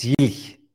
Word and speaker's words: Jilch: It's Jilch. So it's Jilch. At the end, yes.